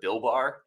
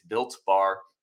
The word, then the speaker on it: Built Bar: I called it Bill Bar, and somebody was trying to find Bill Bars. It's Built Bar.